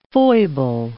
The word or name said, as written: foible